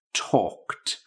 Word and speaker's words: talked: Now remember, when you're using it in that past tense, make sure you get the correct pronunciation of that E-D ending. Talked. It's got a t sound.